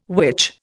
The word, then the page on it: witch: Indian